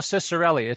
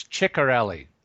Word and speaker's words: Ciccarelli: Dino Ciccarelli, it's Ciccarelli.